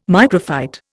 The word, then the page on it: microphyte: Indian